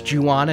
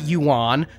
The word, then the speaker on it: Ju-on: I learned a lot about The Grudge and The Curse and Ju-on and all of that, including how to pronounce Ju-on and not Yuan.